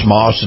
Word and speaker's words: Maus: That's spelled M-A-U-S, Moss, Nissan.